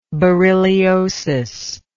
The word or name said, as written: berylliosis